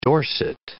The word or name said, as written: Dorset